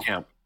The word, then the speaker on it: Blomkamp: Blomkamp? You know what I'm talking about? – Blomkamp.